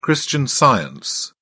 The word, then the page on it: christian science: British English